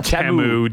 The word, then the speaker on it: Temu: I believe it's pronounced Temu.